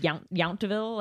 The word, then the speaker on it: Yountville: I think technically it's pronounced Yountville, like okay. Like in Fury, but it's Yountville.